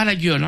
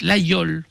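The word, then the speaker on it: Laguiole: Nous sommes allés aussi passer une semaine à L'Aïol, pas la gueule. Hein. On prononce L'Aïol pour les autres.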